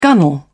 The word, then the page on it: gunwale: British English